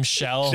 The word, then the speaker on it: Schell: Like, they all have the SCH, which is my last name, Shell, you know, S-C-H-E-L-L.